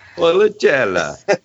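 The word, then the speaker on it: Valpolicella: It's a Val Pacella, I believe is the way you pronounce that. Sure. – Policella. – And it's from Villa Mafia. – Maffei. M-A-F-F-E-I.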